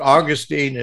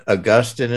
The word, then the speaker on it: Augustine: So what you're saying is the truth here, and that is that Augustine, and I think Augustine is the correct pronunciation, but Augustine is also acceptable.